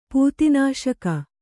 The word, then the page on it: ಪೂತಿನಾಶಕ: pūti nāśaka